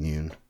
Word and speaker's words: Yun: I do prefer saying Yun. It's an easier name. – That's how it's pronounced.